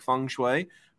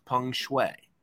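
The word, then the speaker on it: Peng Shuai: Speaking of Snoop, Josh, have you heard about this whole situation with, and you guys are going to think I'm making up the pronunciation of this name because it sounds like feng shui, but it actually is pronounced peng shui. – Peng shui. – Even though it's P-E-N-G, looks like it's peng shui, peng shui.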